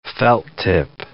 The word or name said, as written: felt-tip